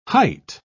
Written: height

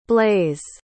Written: blaise

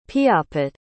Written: piapot

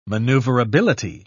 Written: maneuverability

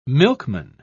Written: milkman

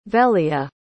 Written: velia

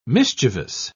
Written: mischievous

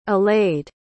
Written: allayed

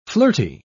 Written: flirty

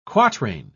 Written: quatrain

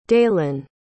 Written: daylon

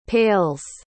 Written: pails

